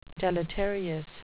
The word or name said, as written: deleterious